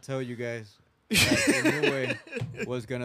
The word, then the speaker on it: Inoue: Inoue. Is that how you pronounce it? – Oh, Inoue for sure.